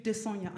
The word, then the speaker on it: Desanya: One of them, the name is spelled D-E-S-A-N-Y-A. How do you pronounce that? – Desanya. – Desanya, okay.